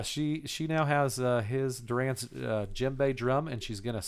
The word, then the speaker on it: djembe: I wanted to make sure that I got that word right because it's spelled with a D. – Yeah. I'm like, I had no idea. I was thinking a twice. – It's D-J-E-M-B-E. That's fucking weird. Djembe. – Just like gin.